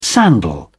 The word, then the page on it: sandal: British English